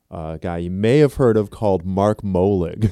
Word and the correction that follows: Malig: So, Malig. Technically, it's Malig. – He's my best friend in the whole world. I know everything about him, especially how to pronounce his name, Malig.